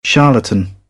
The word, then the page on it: charlatan: British English; ɑː